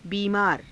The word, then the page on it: biimaara: hindi